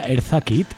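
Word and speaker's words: Eartha Kitt: Bueno, hay que decir que estas canciones de ¿cómo se pronuncia? Erza Kitt. – Sí.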